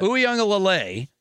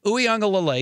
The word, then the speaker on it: Uiagalelei: Uiagalelei. It pretty much is, sounds like how it's spelled if you look at it, because it looks like a lot of letters. Uiagalelei, another SoCal kid.